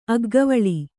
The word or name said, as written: ಅಗ್ಗವಳಿ